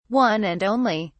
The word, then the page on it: one and only: British English